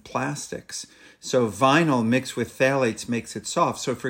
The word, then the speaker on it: phthalates: So, for example, one type of chemicals are called phthalates, which is spelled with a pH, oddly enough, but it's pronounced phthalates.